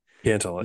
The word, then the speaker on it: Cantlay: Cantlay.